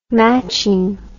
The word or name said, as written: matching